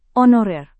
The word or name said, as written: honorer